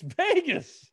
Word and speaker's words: Vegas: Can I say something about how you pronounce Vegas? – I've heard it Vegas before. I don't know. – It's Vegas.